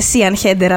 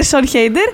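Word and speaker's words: Siân Heder: ξαφνικά έχει βρεθεί η Σον Χέιντερ, που νόμιζα και εγώ ότι προφέρεται Σίαν Χέιντερ, αλλά όχι. Ε, τη λένε Σον Χέιντερ.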